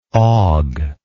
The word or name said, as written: Hogue